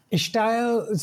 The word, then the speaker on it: Ishtail: Ishtail, so it's a word I grew up hearing, and it's basically accented style. You know, it's the way some people, some Indian people would pronounce style. Mm-hmm. And, you know, that might be in South India, that might sound more like style or estyle.